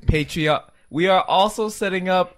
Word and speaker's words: Patreon: We are also setting up a Patron. – Are you just going to say this all the time? – It's pronounced Patreon.